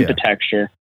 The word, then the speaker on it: Hempitecture: And from there, that's what gives you the push to start Hempitecture. Am I saying that right? – Yep. It's like hemp architecture. – Gotcha. – Hempitecture.